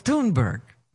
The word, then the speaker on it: Thunberg: No, it's Thunberg. – Is it Thunberg? – Yeah. – Do you know that? – Mm-hmm. – Is that the Swedish thing? Greta Thunberg, even though it's TH.